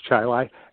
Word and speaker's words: Chili: It's spelt like chili, but it's pronounced Chi-Lai, and that's just south of Rochester.